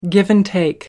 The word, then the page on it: give-and-take: American English